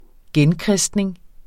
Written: genkristning

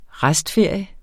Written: restferie

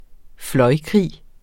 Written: fløjkrig

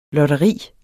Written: lotteri

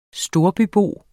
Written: storbybo

